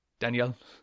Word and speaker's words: Danijel: Well, it's pronounced D-A-N-I-J-E-L. – Oh. – Daniel. – Okay. Just throw some J's in there, Jolie.